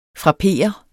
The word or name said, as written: FRAPPERER